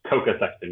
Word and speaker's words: Koka Sexton: Yes, it's Coca Sexton.